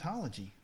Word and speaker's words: pneumatology: pneumatology. I thought, that's pretty accurate. I mean, she got everything except that the P is silent.